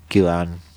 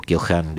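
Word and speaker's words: Keohane: Podemos hablar allí, sí, de interdependencia compleja, Kyohan o Kyohan, depende como quieran ustedes pronunciarlo.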